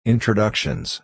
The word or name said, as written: introductions